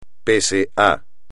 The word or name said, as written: pese a